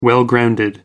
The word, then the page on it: well grounded: American English